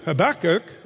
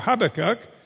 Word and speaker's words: Habakkuk: But if you look into Habakkuk, or Habakkuk, depending on which syllable you put the emphasis, he has got it down to one.